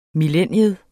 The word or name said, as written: millennium